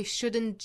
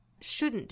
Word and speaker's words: shouldn't: 后面 but 尾部的 t 和 shouldn't 尾部的 t 的音都略掉， but we shouldn't judge them. Shouldn't 这个词也是有两种读法的，可以读成 shouldn't 或者是读成鼻腔爆破的 shouldn't.